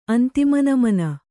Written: ಅಂತಿಮನಮನ

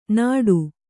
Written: ನಡು

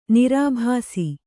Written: ನಿರಾಭಾಸಿ

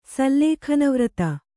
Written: ಸಲ್ಲೇಖನವ್ರತ

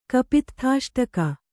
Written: ಕಪಿತ್ಥಾಷ್ಟಕ

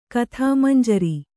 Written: ಕಥಾಮಂಜರಿ

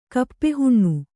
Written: ಕಪ್ಪೆಹುಣ್ಣು

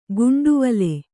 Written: ಗುಂಟವಲೆ

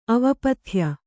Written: ಅವಪಥ್ಯ

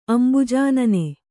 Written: ಅಂಬುಜಾನನೆ

